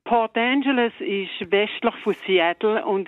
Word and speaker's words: Port Angeles: Von Port Angeles, habe ich es richtig gesagt? – Ja, das ist richtig.